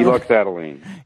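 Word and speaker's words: eluxadoline: Euluxadiline.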